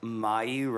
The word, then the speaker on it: Mayu: So, like, Mayu rhymes with Bayou,